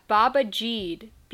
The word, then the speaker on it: Babajide: For some reason, his name was Baba Jew. Or... – Yeah, Baba Jeed. B-A-B-A-J-I-D-E.